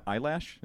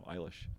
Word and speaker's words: Eilish: How you pronounce it? Eilish? – Yeah. Eyelash? No, Eilish.